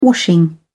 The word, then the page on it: washing: British English